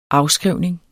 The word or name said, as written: afskrivning